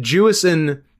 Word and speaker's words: Jewison: And Jewison didn't. Emphasis first syllable.